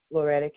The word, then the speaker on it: Loretta: Loretta Cade, C-A-D-E. And Loretta is spelled L-O-R-E-T-T-A.